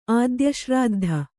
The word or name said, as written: ಆದ್ಯಶ್ರಾದ್ಧ